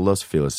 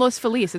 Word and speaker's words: Los Feliz: It's not Los Feliz. It's Los Feliz, right? – Sepulveda. – But I'm just saying, like, people say you're supposed to pronounce it that way, but it's actually not.